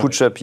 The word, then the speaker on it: PoochUp: Bienvenue à vous aussi, euh, fondateur de PoochUp, vous prononcez ça comme ça C'est ça, exactement. PoochUp, yes, j'ai réussi à bien le prononcer.